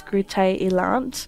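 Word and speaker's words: Groote Eylandt: Groot Island? – So the way it's actually Dutch, but for yeah. Great Island. But the way to pronounce it in Dutch is Groote Eiland.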